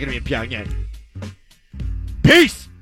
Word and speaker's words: Pyongyang: Tomorrow on the show, Will Graves is in Pyongyang. Am I saying that right? – Sure.